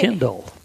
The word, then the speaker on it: Tyndall: And then we have another gift from Anonymous from Tyndall, South Dakota. Did I pronounce that right? – Tyndall.